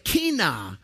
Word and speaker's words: qinah: Now, the Hebrew word for zeal, for you note takers, is kinah, and it's spelled Q-I-N-A-H.